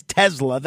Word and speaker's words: Tesla: And yes, it is Tesla. That's how Elon Musk pronounces it. And people ask, why do you say France when that's not how it's pronounced? Yes, it is, because that's how people in France pronounce it. Why do you say the car as Tesla when it was named for Nikola Tesla? It's Tesla because the guy who invented the car says Tesla.